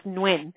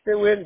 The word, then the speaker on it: Nguyen: And that's G-A-D-O. And then your last name is Nguyen, N-G-U-Y-E-N, correct? – Yes. – Do you pronounce it Nguyen or Nguyen? I know people pronounce it different. I was always raised as Nguyen. – Nguyen, yeah. I mean, Nguyen.